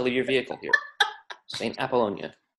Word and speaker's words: Apollonia: A-P-O-L-L-O-N-I-A. – Chris, can you pronounce it for me in Italian? – Apollonia. Oh, in Italian. Oh, in Italiano. Apollonia. Sante Apollonia. Mamma mia, Sante Apollonia.